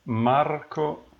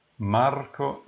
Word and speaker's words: Marco: So it is Marco Musiani. – Oh, yeah, I see that. That's the... Okay, say it again. – Marco Musiani.